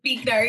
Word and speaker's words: Buechner: Frederick Buechner. I never know how to say his name. – Yep. Buechner.